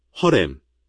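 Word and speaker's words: 허렘 (חרם): 그것은 헤렘, 허렘, 이라고 불리는 원리이고 이것은 20장 17절에 나와 있습니다. 동사 형태는 하람이다. 이제 허렘. 발음 허렘은 단단한 H입니다. 허렘은 완전한 파괴를 의미합니다.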